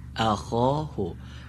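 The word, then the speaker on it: أخاه: اخاهو حرف خا باید درست تلفظ بشه.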